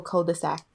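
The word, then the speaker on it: culs-de-sac: So if you're talking about multiple cul-de-sac, it's spelled C-U-L-S de sac. So that's another version. Yeah, but you don't pronounce, you don't say the S.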